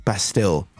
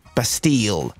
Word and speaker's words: Bastille: By the way, people have been texting me saying I've been saying that wrong. Bastille. Bastille. Is that how I'm supposed to be saying it? – Yes, that'd be fine.